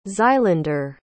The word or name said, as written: xylander